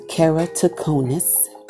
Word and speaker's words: keratoconus: Around that time is when I was diagnosed with keratoconus, and that's pronounced keratoconus.